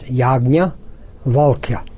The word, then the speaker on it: Yajnavalkya: It's a hard name, but it's pronounced Yajnavalkya.